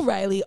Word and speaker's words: Rylee: I spell Riley R Y L E E. Which is not a normal way to spell Riley. Normally, it's R-I-L-E-Y. – Or R-I-L-E-Y or R-E, whatever. – R-I-L-E.